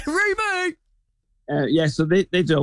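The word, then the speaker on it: REME: You do have to make sure you say it right. It is Remy!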